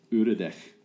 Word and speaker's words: Uradech: Uredek. – Yeah. I yeah. – know that. – All right. Uredek. – Yeah. – I like it. – How do you spell it? – U-R-A-D-E-C-H.